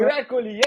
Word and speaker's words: Virat Kohli: Oh, Virat Kohli, yeah.